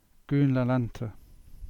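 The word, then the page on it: Lelant Downs: Cornish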